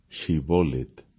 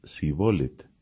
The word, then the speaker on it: Shibolet: Si él respondía que no, le obligaban a pronunciar la palabra Shibolet, pero él decía Sibolet, porque no podía pronunciar correctamente.